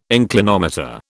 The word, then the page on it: inclinometer: British English